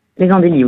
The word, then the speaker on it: Andelys: C'est comme ça qu'on prononce ? Aux Andelys. – Les Andelys, oui, c'est ça.